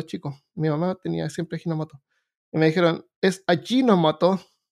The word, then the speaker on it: Ajinomoto: Allí, no, con J, Ajinomoto, pero se pronuncia Ajinomoto porque una vez fui a,